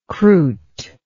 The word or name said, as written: croute